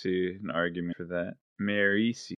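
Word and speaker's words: Marasi: Is that how you say her name? – That's how I say her name. That's how I believe it should be pronounced. That's not how the audiobook does it. – Okay. So, Serini is 70. Marisie is rated higher than Wayne. Notable. – Would you like me to break down the etymology for why I think her name should be pronounced that way? – Yeah, sure. – Okay, well, it's derivative of Mare, Kelsier's wife. – Oh. – So, Mare.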